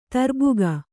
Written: ತರ್ಬುಗ

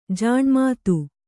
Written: ಜಿನಮತ

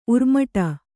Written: ಉರ್ಮಟ

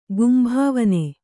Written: ಗುಂಭಾವನೆ

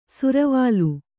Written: ಸುರ್ವಾಲ್

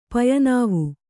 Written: ಪಯನಾವು

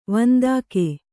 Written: ವಂದಕ

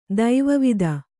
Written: ದೈವವಿದೆ

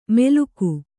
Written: ಮೆಲುಕು

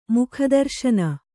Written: ಮುಖದರುಶನ

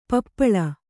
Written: ಪಿಪ್ಪಾಲ

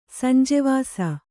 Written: ಸಂಜೀವಿಸು

